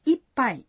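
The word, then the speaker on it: ippai (いっぱい): A pronúncia de ippai é um pouco difícil. – Ippai. – Essa palavra tem um som pausado. Para pronunciar esse som, é preciso fazer uma pequena pausa.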